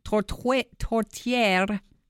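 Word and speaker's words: tourtière: They're going to be serving on the menu poutine, British Columbia salmon, beef from Canada, and tortiere. That's how you say it.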